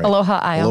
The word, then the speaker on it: Aloha Isle: and check out Aloha, or did I say that right? – Aloha Isle. – Aloha Isle.